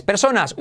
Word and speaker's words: person: La número uno, es ni más ni menos que una persona o varias personas. Una persona, one person. Pronunciación. Letra genérica, la letra genérica R se come a la vocal que lleve delante, da igual cual sea, en este caso person.